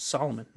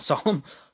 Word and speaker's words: Solomon: It's Solomon, not Solemn.